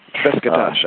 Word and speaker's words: Beşiktaş: Beşiktaş, I think.